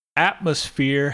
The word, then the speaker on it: atmosphere: Atmosphere has three syllables, and the first syllable should be on the at, atmosphere.